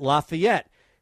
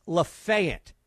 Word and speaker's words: Lafayette: Whenever you see that word, Lafayette, you pronounce it Lafayette. In this case, it's Lafayette.